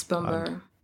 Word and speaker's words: Bambara: Bambara? – Yes, Bambara.